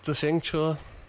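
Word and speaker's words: Zixingche (自行车): Zixingche.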